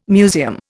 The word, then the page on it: museum: Indian